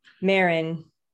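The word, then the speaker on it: Maren: If I'm saying her name right, I think it's M-A-R-E-N, Marion. – Marin. – Yeah. There you go.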